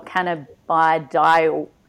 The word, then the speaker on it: cannabidiol: So get a bit of basics with, I'm going to have trouble pronouncing these words as well, cannabidiol.